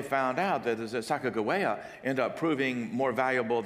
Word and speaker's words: Sacagawea: Charbonneau, who they had hired to travel with them, had a wife from amongst the Shoshones, whose name was, we pronounce it Sacagawea. Most scholars say that it should be pronounced probably Sacagawea.